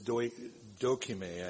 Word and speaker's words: dokime: The Greek word used for character in Philippians 2:22, or Paul is speaking of, I'm sorry, in Philippians 2:22, it's dokime, I think that's how you say it, D-O-K-I-M-E.